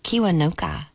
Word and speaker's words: Kiwanuka: One More Night with Michael Kiwanuka, Love and Hate. I butchered that. It's Kiwanuka. I'm not quite sure. K-I-W-A-N-U-K-A.